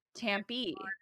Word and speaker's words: Tampe: Yeah, it's Tampe.